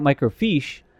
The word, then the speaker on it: microfiche: But if you're looking at microfiche, then... – Fish. – That's how it's pronounced.